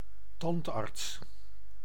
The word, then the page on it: dentiste: France; /dɑ̃.tist/